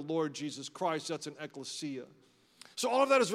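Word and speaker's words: ekklesia (ἐκκλησία): It's called ekklesia. It's spelled pretty much just as I'm pronouncing it, ekklesia, and it means called out ones, and the church is called out.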